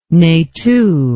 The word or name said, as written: nethou